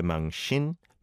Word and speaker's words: 망신: It's not a sin sound, but a shin. When the shiot and the e vowel sound come together, it almost changes the shiot to a sh sound, right? 공개 망신, 망신 당했어.